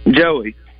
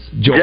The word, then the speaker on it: Joey: Joey. – Joy. – Okay, pronounce that again, Baz. – Joey. J-O-E-Y.